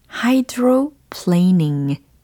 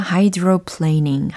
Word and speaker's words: hydroplaning: 음, 자, 먼저 수막 현상은 hydroplaning 이렇게 표현을 해보시면 좋겠어요. 특히 이 plane 들으셨죠? plane. 여기에서 ing로 바꿔서 planing이라고 발음을 한 거거든요.